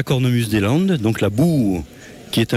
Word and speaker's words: boha: le nom est un peu piégeux parce que en gascon on l'écrit B-O-H-A, mais ça n'est pas un bois. Et comme le O se prononce OU et le A final se prononce O, c'est vraiment pas la simplicité, mais c'est voilà.